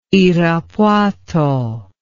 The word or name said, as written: irapuato